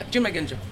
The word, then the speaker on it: Junmai Ginjo: Okay, sake number one, Song of the Sea, Junmai Ginjo. Yes, so did I say that right? – Yeah, Junmai Ginjo.